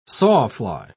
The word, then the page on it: sawfly: British English